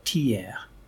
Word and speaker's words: Thiers: It's manufactured in an interesting city in central France called Thiers, that's how you pronounce it.